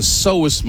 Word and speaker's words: psoas: Well, the main muscle that does that is the psoas muscle. It's spelled funny because it's with a P, P-S-O-A-S.